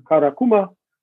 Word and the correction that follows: Kamakura: And the first week that you lived in how do you say it correctly, Kamakura.